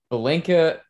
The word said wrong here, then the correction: Bjelica: however you say his name. I'm sorry. – Nemanja Bjelica. – Bjelica, that's how you say it, is a good pickup.